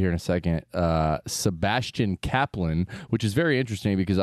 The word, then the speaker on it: Cappelen: which is spelled C-A-P-P-E-L-E-N. My best friend in the world, Ryan Capellan, and his brother Stevie and Rusty are the Capellans. Sebastian told me they pronounce it Kaplan, which is Danish because Sebastian is from Denmark and we played today.